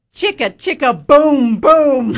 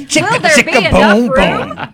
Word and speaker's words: Chicka Chicka Boom Boom: You got to say it right. – Chicka Chicka Boom Boom. – Yes. – Chicka Chicka Boom Boom.